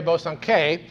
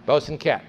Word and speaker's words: Bosanquet: Now, any French scholar will tell you that should be pronounced Mary Bosanquet, but she wasn't French, she was English. Her ancestors had been French and they had come to England, and their name, like the language they spoke, had been anglicized, and the French, the English have always pronounced it with the hard T at the end, Bosanquet.